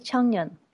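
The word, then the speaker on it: Changnyeon (작년): Ça s'écrit Changnyeon, mais pour harmonisation vocalique, ça se dit Changnyeon.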